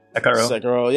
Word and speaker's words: Sekiro: Neither Sekiro or Sekiro. – Sekiro. Yeah, yeah. It's Sekiro. Like a goddamn Japanese game.